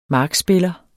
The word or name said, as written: markspiller